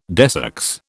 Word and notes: desex: British English

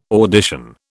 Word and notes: audition: British English

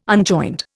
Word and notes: unjoined: Indian

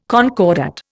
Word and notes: concordat: Indian

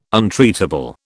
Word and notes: untreatable: British English

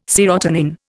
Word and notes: serotonin: Indian

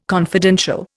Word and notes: confidential: Indian

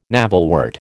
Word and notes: navelwort: American English

